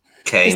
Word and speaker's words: Keynes: Praticamente mh, mm, insieme a Keynes, spero che si pronuncia così, John Maynard Keynes, Keynes.